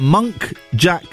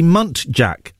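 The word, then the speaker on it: muntjac: I recently found out that monk jack deer are actually munt jack. That took me a long time to get. Monk jack and munt jack.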